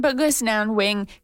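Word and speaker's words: Bkejwanong: Kiknazwe is Cree from the Buck has, I'm going to say this wrong, Buck has Wanang, let me try that again, Bugisnanwing territory, also known as the Walpole Island First Nation in southern Ontario.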